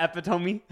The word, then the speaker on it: epitome: And like Epitone, is that one? – Oh, yeah, epitome.